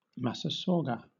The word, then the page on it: massasauga: British English